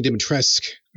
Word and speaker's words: Dimitrescu: The first section with Lady Dimitrescu, is how they pronounce it, which kind of threw me for a loop, was fairly scary.